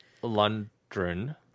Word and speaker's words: Lundgren: Am I supposed to pronounce the D or the G? – It's Lundgren. – Lundgren. – Yeah. – Lundgren. – Correct me if I'm wrong, sweetie. – It's he, Lundgren. Man.